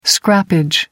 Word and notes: scrappage: American English

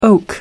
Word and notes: oak: American English

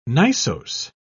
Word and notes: gneissose: British English

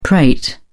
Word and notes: prate: British English

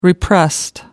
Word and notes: repressed: American English

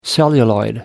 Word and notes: celluloid: American English

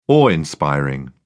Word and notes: awe-inspiring: British English

